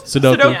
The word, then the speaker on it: sudoku: Sudoku? – Sudoku.